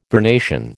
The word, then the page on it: vernation: American English